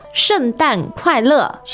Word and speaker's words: 圣诞快乐: So you have four fourth tones in a row there. Let's hear them. – 圣诞快乐。